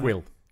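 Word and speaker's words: Kuiil: Is it Kull or Quill? How do you pronounce it? – Quill. – Yeah, Quill. Yeah.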